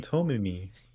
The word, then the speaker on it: Tomie: It's because I've been pronouncing her name so fucking bad. – Oh, you mean Tomie? – Pretty much.